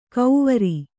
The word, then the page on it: ಕೌವರಿ: Kannada